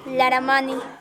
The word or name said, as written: Apu Laramani